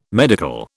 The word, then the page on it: medical: British English